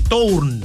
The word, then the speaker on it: Tourn: No, por eso sí, era, Fidel Tourn. T-O-U-R-N, Tourn.